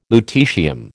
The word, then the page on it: lutetium: American English